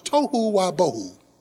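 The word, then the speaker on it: tohu wabohu (תֹהוּ וָבֹהוּ): The Hebrew word for formless and void is pronounced tohu wabohu.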